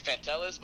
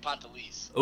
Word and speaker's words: Pantelis: So a lot of people think it's Pantelis, but it's actually Pantelis.